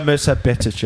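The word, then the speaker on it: Mirsad Bektić: Mirsad Bektic, yeah.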